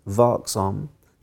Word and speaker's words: vaksam: And unless we are, I've learned this Swedish word, unless we are vaksam, mm. vigilant, vaksam, did I say it right? – Yeah. Yeah.